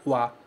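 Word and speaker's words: wa (は): A gente escreve ha, mas pronuncia wa.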